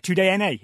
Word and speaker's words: TUDN: Yeah, TUDNA is how I think you are supposed to pronounce that.